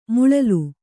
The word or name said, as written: ಮಲ್ಲಲಿ